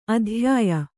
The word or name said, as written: ಅತ್ಯಯ